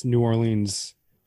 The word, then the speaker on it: New Orleans: My father was a gambling man down in New Orleans. – Oh my God. – Yikes. – I should have technically pronounced New Orleans, New Orleans, to rhyme with jeans.